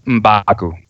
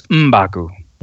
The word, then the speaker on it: M'Baku: M'Baku. – Baku, yeah. – It's M'Baku. – M'Baku, yeah.